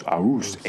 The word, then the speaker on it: Aarhus: Aarhus. Aarhus. A-A-R-H-U-S.